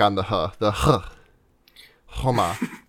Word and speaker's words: Khoma: Okay, so forgive the pronunciation question, but the K is silent in his name, Homa. – Yeah, I don't think there is a K in the Russian version. – Huh. In the subtitles that I was getting, it was K-H-O-M-A, but I mean, I'll defer to you.